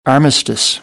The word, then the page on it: armistice: American English